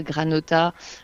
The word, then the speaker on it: Granhota: Mais bon, quand même, pour les auditeurs qui nous écoutent, hein, vous l'avez compris, la Granota, alors GRANHOTA, Granota, si on ne le prononce pas en occitan.